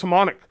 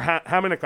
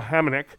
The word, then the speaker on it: Hamonic: So first, let's talk about Travis Hamonic. Or Hamonic, I apologize. Somebody pointed out to me that I've been saying that wrong. So Hamonic, assigned to Abbotsford.